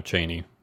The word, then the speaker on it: Cheyne: His family moved to Colville, Washington, where they were neighbors, and Israel was friendly with Chevie and Cheyenne. No, Cheyne. C H E Y N E. Cheyne.